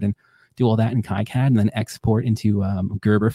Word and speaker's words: KiCad: Yeah, KiCad.